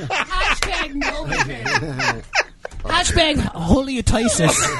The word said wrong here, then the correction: halitosis: How do you say that word? – Halitosis.